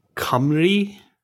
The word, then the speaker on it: Cymru: The word we're looking at is spelled C M C Y M R U. I'm sure it has some bizarre pronunciation that I've never heard of. Cymru. Cymru? Cymru. Who knew?